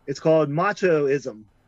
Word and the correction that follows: Machismo: Machoismo. 40 Graves for 40 Guns. I think I'm saying that right. – Harry Novak presents the first motion picture bold enough to be called Machismo.